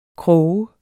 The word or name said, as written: krog